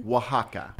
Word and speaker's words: Oaxaca: So Oaxaca, fantastic food region. – Which is an inland state, right? – And way south, south. – Right, okay. – And it's, say the name of it again. – Oaxaca. – But that's spelled like O-A. – O-A-X-A-C-A.